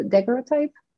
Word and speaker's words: daguerreotype: And if it's the exact version of the daguerreotype, I'm not sure how to pronounce it either. – You pronounced it right, yeah.